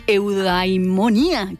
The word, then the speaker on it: eudaimonía: De hecho, hay una relación íntima entre filia, que proviene del griego filia y significa amistad, y eudaimonía, que es felicidad.